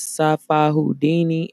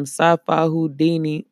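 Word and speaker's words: Psyfi Houdini: Yes, y'all, that was Sci-Fi Houdini, and that is spelled P-S-Y-F-I Houdini. You could get more music from P-S-Y. God damn it. You could get more music from Sci-Fi Houdini on Spotify, so search Sci-Fi Houdini. That's P-S-Y-F-I Houdini on Spotify.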